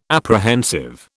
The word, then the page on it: apprehensive: British English